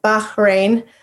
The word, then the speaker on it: Bahrain: I got married, and my husband was stationed in Bahrain, and then actually you pronounce it Bahrain. My friend's from there, so she's always correcting me on how to pronounce it.